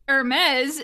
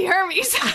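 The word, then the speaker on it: Hermès: And speaking of Lux brands, Hermes is literally Hermes, but pronounced fancy.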